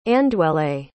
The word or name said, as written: Andwele